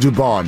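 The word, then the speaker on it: Dubón: Dubon. It's pronounced Dubon. Mauricio Dubon.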